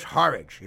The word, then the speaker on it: Horridge: Then there's Milagros Horridge. It rhymes with porridge, but it's not as lumpy.